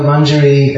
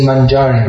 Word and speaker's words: Manjari: Lavanga Manjari in Chaitanya Leela is Sanatana Goswami. Please notice the pronunciation, they usually say Manjari, but it should be Manjari.